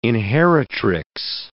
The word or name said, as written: inheritrix